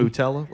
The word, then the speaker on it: Boutella: Is that how you say it? Like Nutella, but with a B? – Boutella, let's say that.